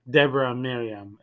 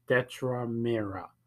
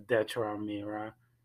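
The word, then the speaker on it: Dedra Meero: It's Deborah Miriam. It's not Deborah Miriam. I said it wrong in last podcast. It's Detra Mira. Thank you for those emails, guys. And sorry about that. I said Deborah Mira instead of Detra Mira.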